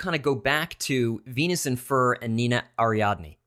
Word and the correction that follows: Arianda: Or Arianda.